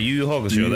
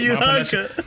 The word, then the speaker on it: Yu Yu Hakusho: Yeah, the Yu Yu Hakusho. That's the way I say it. Like, Yu Yu Hakusho.